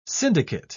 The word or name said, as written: syndicate